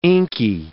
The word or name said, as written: inky